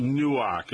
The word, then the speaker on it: Newark: They pronounce it Newark, not Newark. – Newark.